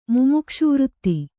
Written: ಮುಮುಕ್ಷುವೃತ್ತಿ

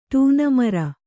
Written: ಟೂನಮರ

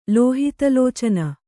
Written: ಲೋಹಿತಲೋಚನ

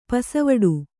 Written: ಪಸವಡು

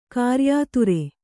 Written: ಕ್ರಯದಾರ

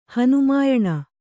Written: ಹನುಮಾಯಣ

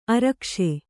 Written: ಆರೊಗಿಸು

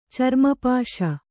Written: ಚರ್ಮಪಾಶ

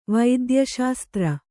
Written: ವೈದ್ಯಶಾಸ್ತ್ರ

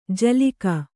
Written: ಝಿಲ್ಲಿಕೆ

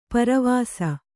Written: ಪೆರ್ವಸ

